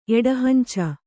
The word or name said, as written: ಎಡಹಂಚ